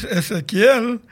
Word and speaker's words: Ezequiel: They would call me Ezequiel, Ezequiel, and my name is Ezequiel.